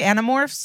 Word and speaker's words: Animorphs: Animorphs? – Yeah.